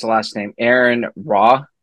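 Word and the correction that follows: Rai: Aaron Rye, do you know who that is?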